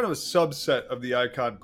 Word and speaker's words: Eikon: One is the, I want to get the pronunciation correct, the Icon Podcast, E-I-K-O-N. – Correct. – The Icon Podcast.